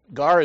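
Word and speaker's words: gar (γάρ): Now, the word gar, which we pronounce as G-A-R, this is how it's pronounced or how it's spelled in Greek, a transliteration of that, meaning how would you say that in English, is gar.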